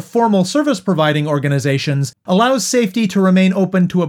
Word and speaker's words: SAEFTY: The group is called Safety Ottawa. That's spelled S-A-E-F-T-Y, and it stands for Support and Education for Trans Youth.